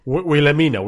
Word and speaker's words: Wilhelmina: La seva primera criada, la senyora eh, Vilhimne, no sé si es pronuncio bé. – Wilhelmina, Wilhelmina. – Uh, ah, sí, sí, Wilhelmina, disculpa.